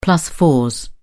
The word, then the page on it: plus fours: British English